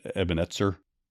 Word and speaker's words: Ebenezer: I believe the actual pronunciation is Ebenezer.